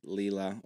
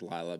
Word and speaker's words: Lila: There's a part in a book called Leela or Lila. – Yes. – I prefer Leela. I think it's pronounced Lila, but anyway, by Robert Persig.